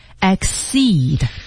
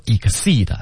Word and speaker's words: exceed: 好，我们一起来看第一个 ,exceed, 它的读音是 exceed。 – 好多同学会读 exceed, 对吧，不能这么读啊。 – 所以今天老师再把这个发音给我们读一下。 – 来啊 ,exceed,exceed。注意重音在 seed 上面。前面读 x。